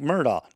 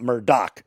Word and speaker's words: Murdaugh: The last name is Murdaugh, pronounced like Murdaugh, not Murdoch. The G-H is silent and no K sound.